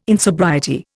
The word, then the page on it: insobriety: Indian